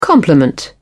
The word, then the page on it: complement: British English